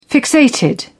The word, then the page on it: fixated: British English